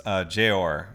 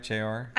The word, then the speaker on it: Jeor: Jeor, I believe. Is that right, Jeor? – I think that's how you pronounce it.